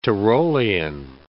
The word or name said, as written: Tyrolian